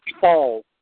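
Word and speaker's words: Faul: Yeah, it's pronounced fall with an F, F A U L.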